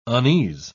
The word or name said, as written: unease